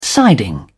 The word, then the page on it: siding: British English